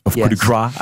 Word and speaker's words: Coup de Grace: Coup de Grace, I had to say.